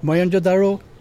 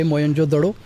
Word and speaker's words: Mohenjo-daro: Mohenjo-daro. I pronounce it the local way, Mohenjo-daro.